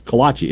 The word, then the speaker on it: Kolache: Also, I grew up and I've always pronounced Kolache with three syllables. Kolache. Isn't that some people say Kolache? I think both are acceptable.